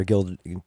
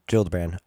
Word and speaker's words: Gillibrand: Gild, Gillibrand.